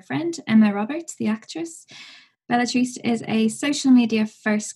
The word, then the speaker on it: Belletrist: We say Belletrist, but you know, that's just kind of how we say it. Trist, I guess, would be sad. It's more T-R-I-S-T.